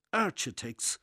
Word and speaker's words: architects: They pronounce the CH the same as the CH in cheese, architects.